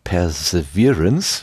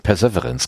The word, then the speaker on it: Perseverance: Ja, äh, anders als alle anderen Rover hat ja dieser Perseverance, jetzt kann ich es glaube ich auch richtig aussprechen, also dieser Virance wird, muss betont werden. Ich, ich habe ja letztens Perseverance gesagt.